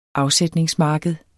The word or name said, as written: afsætningsmarked